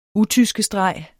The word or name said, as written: UTYSKESTREGERS